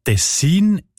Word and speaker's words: Tessin: Ja, tatsächlich variiert die Betonung von dem Kantonsnamen, wo sich übrigens vom gleichnamigen Fluss ableitet, von Region zu Region oder sogar von Person zu Person. Die Variante Tessin ist häufiger.